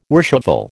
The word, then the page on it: worshipful: American English